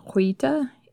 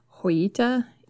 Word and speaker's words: Joyita: I've heard it pronounced as Joyita in relation to Roland West and her early life. – Okay. – But if you listen to Polynesian and New Zealand sources in this story, they use the hard J, and that's how her last captain pronounced it as well.